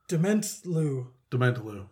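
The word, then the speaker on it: Dementlieu: Dementlau. – Yes. – Dementlu. – Demon... – Nope. – To Liu. – Dement. – Liu. – Like Lieutenant. – Dementlu. – That's how I'm pronouncing it.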